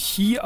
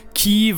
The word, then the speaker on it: Chi: Also der griechische Buchstabe im Deutschen spricht man ihn, glaube ich, Chi aus, aber im Englischen sagen sie immer Ki, was natürlich eine Referenz ist auf das Keyblade von Kingdom Hearts, aber das kommt ja bestimmt noch.